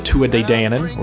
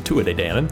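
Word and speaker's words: Tuatha Dé Danann: And then they go to war with this other supernatural group called the Tua de Danann, or the Tuatha de Danann, however you want to pronounce it. In Ireland, it's the Tua de Danann.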